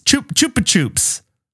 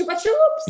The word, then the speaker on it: Chupa Chups: Is it Chupa Chips? Chupa Chups. – Oops, Chupa Chups.